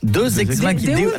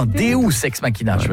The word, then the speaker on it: Deus ex machina: Deux ex machina. Non, Deus ex machina. – Ah, parce que deux, j'aurais dit que c'était deux ex que t'appelles machine. Tu vois, ça se prononce, ça se prononce Deus ex machina.